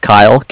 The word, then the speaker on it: Kyle: It's Kyle, K-Y-L-E.